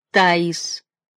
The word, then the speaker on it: Таис: В двусложных словах и именах ударение ставится, собственно, на первом слоге. Таис, Эрис.